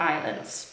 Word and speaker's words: island: The south of the country is filled with islands, czyli wypełniony, filled with islands. Wypełniony wyspami. Pamiętajmy, że island jest tam S, ale tego S się nie wymawia.